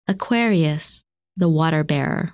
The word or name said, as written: Aquarius